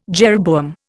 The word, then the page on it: jeroboam: Indian